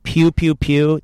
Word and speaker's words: PKEW PKEW PKEW: Pew, pew, pew. That's spelled P-K-E-W, P-K-E-W, P-K-E-W.